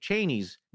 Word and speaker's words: Cheney: But Dick Cheney's name is actually pronounced Cheney. – Oh, everybody says it wrong.